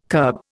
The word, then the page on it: kerb: Indian